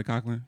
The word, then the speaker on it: Culkin: Oh, uh, Macaulay Culkin.